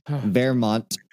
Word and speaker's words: Wehrmacht: Wehrmacht officers. And the reason why I had to look it up is because it's spelled with a W but pronounced with a V in English.